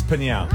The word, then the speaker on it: Pinau: Is it Pinau? – It's Pinau. – Pinau. – Pinau. – Okay, Pinau. – There we go. – Pinau. – Pinau. – It's like an onomatopoeia.